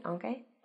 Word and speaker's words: aunque: aunque or although is what I was saying. We shorten it to though, but aunque is the word in English or in Spanish. Am I saying that right? Aunque? Aunque.